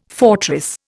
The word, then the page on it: fortress: Indian